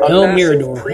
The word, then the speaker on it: El Mirador: I'm with archaeologist Dr. Richard Hansen, exploring the jungle ruins of El Mirador. – El Mirador. Hey, I actually pronounced it right.